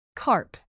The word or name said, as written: carp